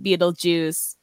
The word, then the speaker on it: Betelgeuse: but then in the movie it's Betelgeuse and they say it Beetlejuice,